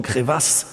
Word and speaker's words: Crevasse: Crevasse, französisch ausgesprochen Crevasse.